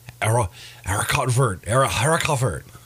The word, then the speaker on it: haricot vert: Demi-glace with roasted garlic, mashed potatoes, and haricot vert, basically green beans. – Okay. – That's the word I was trying to learn how to pronounce off air. – That's the way you say it.